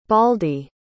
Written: baldy

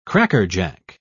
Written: crackerjack

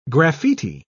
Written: graffiti

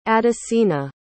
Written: adesina

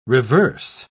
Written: reverse

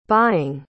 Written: buying

